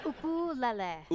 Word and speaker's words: ukulele: Peter's first question to Taimane: how the hell do you pronounce ukulele? – Ukulele. – Ukulele. – Uku. – Ukulele. Lele. – You got it.